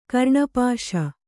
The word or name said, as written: ಕರ್ಣಪಾಶ